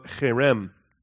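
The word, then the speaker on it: cherem (חרם): And allergy season is great for pronouncing cherem, because you've got to get that in the back of your throat, okay? Or any German speakers that are accustomed to the guttural stuff back there, all right?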